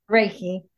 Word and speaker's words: Reiki: Am I saying that right? Reiki, Reiki? – Reiki. – Reiki, Reiki, that's what it is.